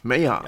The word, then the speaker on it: Meijar: Ich habe gefragt, Major werden sie ausgesprochen. – Meyer, ja. okay. Und, und, zwar wird es buchstabiert äh, M-E-I-J-A-R.